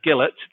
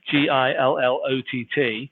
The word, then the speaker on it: Gillott: I'm a real Iain uh, Gillott, G I L L O T T.